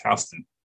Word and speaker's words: Houston: Um, you in Houston, we have a county that has the same spelling, Houston, but it's not pronounced that way. It's pronounced Houston.